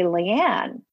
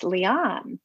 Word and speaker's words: Liane: And she said, well, you all say Leanne, which I've been saying Leanne Moriarty. And I said, she goes, well, it's Leanne.